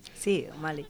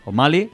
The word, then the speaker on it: O'Malley: Que nos llega de Colmenar Viejo, su local Grace O'Malley, ¿lo digo bien? – Sí, O'Malley. – O'Malley.